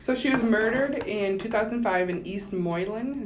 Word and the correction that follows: Moline: Is that how you say it? – I think it's Moline. – Moline, Illinois.